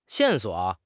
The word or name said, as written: 线索